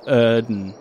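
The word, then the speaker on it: Yrden: Erden. Because it's Y-R-D-E-N. – That's Erden. – Right. – That's right. – As in Earth. Erden.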